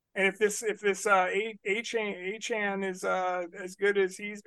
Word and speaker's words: Achane: Devon Achane, Achan. – It's Achan. – Achan is okay. The pronunciation. Achan.